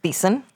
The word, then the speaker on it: Thiessen: Thiessen.